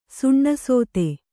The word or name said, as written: ಸುಣ್ಣಸೋತೆ